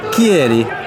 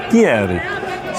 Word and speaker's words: Chieri: Chieri. – Chieri. – So is it a CH name? – Yeah. – That's pronounced with a hard K.